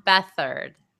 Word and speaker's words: Beathard: It is still unknown how the chemistry is going to be between Garçon and their new starting quarterback for the 49ers, CJ Beathard. How do you pronounce his last name? – Oh, sorry, I was typing. Muted. Beathard.